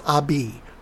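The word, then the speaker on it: Ahbez: I learned how to say Abez a couple of years ago. It's pronounced Abi.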